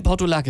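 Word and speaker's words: Portulak: Und kam vor kurzem an mit Portulac. Und ich dachte, das ist Portulac, das ist jetzt falsch ausgesprochen. Portugal heißt, ist ein Land, ist keine Pflanze. Nein, Portulac, es wäre eine Pflanze. Gut, dann habe ich mich ein bisschen damit beschäftigt, dachte ich, oh krass, das wächst ja sogar hier bei uns in der Lausitz.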